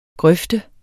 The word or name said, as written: grøft